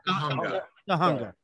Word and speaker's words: Tujunga: called Tujunga. Am I saying that right? You don't really see that part. – Tujunga.